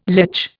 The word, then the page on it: lech: Indian